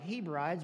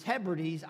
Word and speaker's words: Hebrides: It looks like Hebrides, but it's Hebrides Islands, was surrounded one night by locals, and they were mad, and they were ready to kill him.